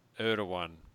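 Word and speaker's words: Erdoğan: Erdogan. – Not the way it looks like it's pronounced,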